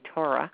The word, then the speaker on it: Torah: I tell Baal Tshuvas I write it out for them how to pronounce Torah, because you can tell a BT often because I say Torah, and you hear the or more. So I write it at T-O-E-R-A-H, Torah, right?